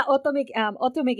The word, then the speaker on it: otome: But I think there's, at least in my opinion, I'm hoping there's a little, and maybe you can speak of this, because is it Otome Games, is that how it's pronounced? – Yeah, Otome Games.